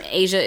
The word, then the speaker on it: Aja: Ask Asia podcast, yes. – And Asia is spelled A-J-A. – Yeah, like the Steely Dan album.